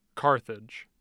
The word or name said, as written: carthage